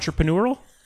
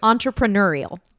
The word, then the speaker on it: entrepreneurial: Entrepreneurial? Am I messing it up? – Yes. – Oh man, I should not do Cameo. You say it right. – Entrepreneurial.